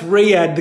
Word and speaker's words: Riyadh: How do you say that city? – That's Riyadh, the capital of Saudi Arabia.